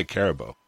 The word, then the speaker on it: Karibow: Karibow was perfect. – Okay, that's good. I never knew how to say that. I've played you before, and I wasn't sure how to say it. But okay, Karibow. Okay.